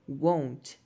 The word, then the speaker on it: won't: E aí você pode falar won't. Cuidado com a pronúncia, porque tem want. Want é querer. Won't. Fecha mais a boca, faz o som do O.